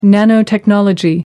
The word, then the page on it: nanotechnology: American English